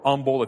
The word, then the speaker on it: humble: I think it would help us sometimes to be more humble or humble if you don't pronounce the H.